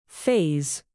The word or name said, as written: phase